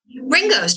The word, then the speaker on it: Ringoes: It's located in, how do you pronounce that, Ringo's? – Ringo's, just like the beetle.